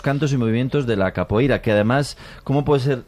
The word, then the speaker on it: capoeira: Pues todos sabemos lo que es la copeira, ¿verdad? – Capoeira. – Qué mal pronuncio últimamente.